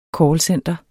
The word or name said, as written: callcenter